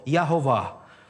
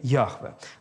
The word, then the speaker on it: Яхве: И э, поскольку это имя для евреев непроизносимо, они от Адонай, Господь, они добавили огласовки, и вот так вышло искусственное слово с двух слов – «Ягова». Но на самом деле большинство э, филологов, э, знатоков древнееврейского языка говорят о, о э, том, что все-таки правильное произношение Яхве.